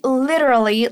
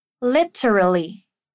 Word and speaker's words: literally: Essa palavra, literally, pode ter duas pronúncias. Repete comigo aí. Ali. Terá. Terali. Lit. Literally. Literally. Ou podemos encurtar isso e dizer literally. Repeat. Ler. Li. Literally.